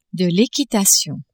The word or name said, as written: equitation